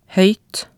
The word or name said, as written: hoeyt